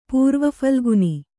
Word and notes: ಪೂರ್ವಫಲ್ಗುನಿ: pūrva phalguni

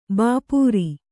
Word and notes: ಭಪ್ಪರೆ: Kannada

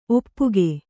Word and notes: ಒಪ್ಪುಗೆ: Kannada

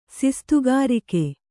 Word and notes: ಸಿಸ್ತುಗಾರಿಕೆ: sistugārike

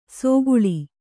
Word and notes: ಸೊಗುಳಿ: kannada

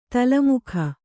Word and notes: ತಲೆಮಗ: Kannada

